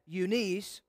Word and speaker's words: Eunice: Eunice, or some would pronounce that, Eunice, and I am persuaded that in thee also.